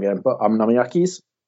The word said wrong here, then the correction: Okonomiyaki: Uh, Okonomiyaki. – Okonomiyaki, well done.